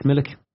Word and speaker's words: Milik: Arcadius Milik.